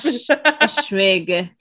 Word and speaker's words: Eschwege: She's looking up the pronunciation. Landgrafenschloss Eschwege. There you go. Do that. Ashwiga. Chantalaga Ashwig. Ashwiga. Okay. Ashwiga.